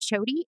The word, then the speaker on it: Choti: And then my name is Annette Choti, and you can find me on LinkedIn. My last name is spelled Choti.